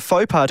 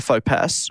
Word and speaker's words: faux pas: Okay, I'll get the etymology for you as well, but it is spelled F-A-U-X space P-A-S. So it would be a faux pas to call it a faux pass or a faux pass. – Fox pass.